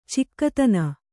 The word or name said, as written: ಚೊಕ್ಕುತನ